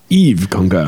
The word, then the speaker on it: Yves Congar: Eve Congar. – Yves Congar. – Oh, Yves. – He was French, huh? – Yeah? – Yeah. Yves Congar.